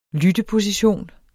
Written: lytteposition

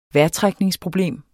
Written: vejrtrækningsproblem

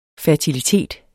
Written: fertilitet

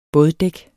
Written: båddæk

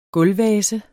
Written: gulvvase

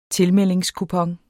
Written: tilmeldingskupon

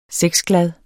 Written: sexglad

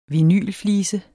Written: vinylflise

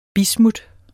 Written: bismut

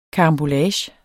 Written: karambolage

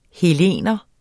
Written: hellener

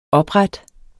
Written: oprette

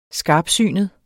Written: skarpsynet

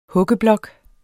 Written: huggeblok

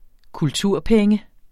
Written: kulturpenge

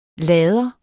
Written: lader

